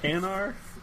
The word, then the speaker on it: Hanar: Hanar? – Yeah.